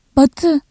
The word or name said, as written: batı